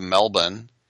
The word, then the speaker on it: Melbourne: And apparently there was a person who flew in from Doha to Melbourne. I said Sydney. I guess it's Melbourne. Never pronounce the R.